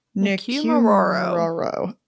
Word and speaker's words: Nikumaroro: The first thought is that they crashed near Nikumaroro. – Nikumaroro.